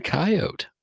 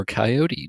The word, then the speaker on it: coyote: And looked down, and holy hallelujah, there was a very well-fed coyote, or coyote, depending on the pronunciation style you use.